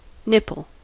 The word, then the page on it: nipple: American English